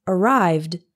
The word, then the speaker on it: arrived: For example, the verb arrive has two syllables, and the past tense is arrived. It also has two syllables. Don't say arrived with three syllables. That's not correct.